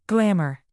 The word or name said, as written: glamor